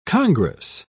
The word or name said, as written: congress